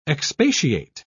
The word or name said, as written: expatiate